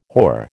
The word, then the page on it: whore: American English